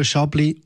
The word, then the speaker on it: Chablis: Chablis geschrieben, aber Chablis ausgesprochen. Chablis ist einer der bekanntesten Weißweine der Welt.